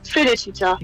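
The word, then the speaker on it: Felicità: Felicita. – Ganz genau. Sogar mit richtiger Aussprache und allem dabei.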